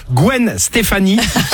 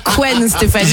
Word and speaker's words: Gwen Stefani: On va se faire plaisir également en playlist 2000 avec, je le dis bien cette fois-ci, parce que sinon, je vais me faire reprendre de voler Gwen Stefani. – Et non pas Gwen Stefani.